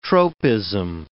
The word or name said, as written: tropism